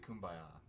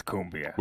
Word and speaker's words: cumbia: It's cumbia. – We're winging it. – Cumbia. – Cumbia. – Cumbia. – Like Colombia.